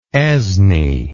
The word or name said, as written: esne